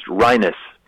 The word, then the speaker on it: Reines: His name is R-E-I-N-E-S, but it's pronounced Rhinus.